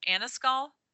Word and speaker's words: Annascaul: And then I left, and I went on my journey to, I might be pronouncing it incorrectly, but it's Annascaul, towards Dingle. – Yeah, I think you're pronouncing it right, yeah.